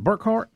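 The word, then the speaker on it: Burkhardt: Is it Burkhart? Is that how you say it? – Yeah.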